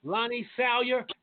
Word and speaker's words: Lonnie Salyer: So my special guest tonight, Lonnie Salyer.